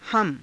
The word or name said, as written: hama